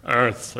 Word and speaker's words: Eaarth: A couple of years ago, McKibben wrote another book called Earth, spelled E-A-A-R-T-H, Earth. I don't know. He suggests that to pronounce it, you have to summon up your inner Schwarzenegger, Earth, or something like that.